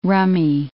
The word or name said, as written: rummy